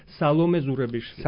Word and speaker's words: Саломе Зурабишвили: В грузинском языке Саломе. На всех словах первое ударение, на первом слоге всегда. Саломе Зурабишвили.